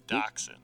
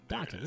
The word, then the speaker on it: Doctson: Hell yeah or hell no, will Josh Doctson catch a ball? – I can't say his name for whatever. Doctson. – Doctson. You got like the dog. Like the little wiener dog.